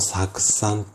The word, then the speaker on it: 酢酸: OK ですかで、この次なんですけれども、アセトアルデヒド脱水酵素っていうものによって、酢酸、酢の酸って書いて酢酸って読むんですが、これに分解されます。さらに、またちょっと複雑になりますよ。この酢酸っていうのは、